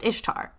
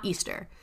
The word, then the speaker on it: Ishtar: which is pronounced Ishtar, not Easter.